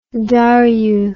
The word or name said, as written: Darrieu